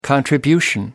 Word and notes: contribution: American English

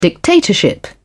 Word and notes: dictatorship: British English

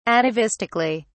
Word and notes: atavistically: British English

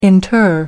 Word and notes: inter: American English

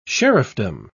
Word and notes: sheriffdom: British English

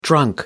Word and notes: drunk: American English

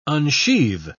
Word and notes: unsheathe: British English

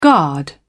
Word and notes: guard: British English

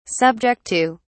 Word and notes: subject to: British English

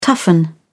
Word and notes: toughen: British English

